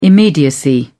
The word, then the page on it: immediacy: British English